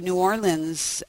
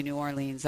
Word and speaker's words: New Orleans: and even though you're not supposed to say New Orleans, I say it because it rhymes with queen and Halloween.